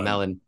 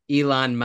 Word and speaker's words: Elon: And the fact that you want to sacrifice, sorry, by you, I mean Elon Musk. I don't mean you. I'm saying his name wrong, aren't I? I'm saying it like Melon.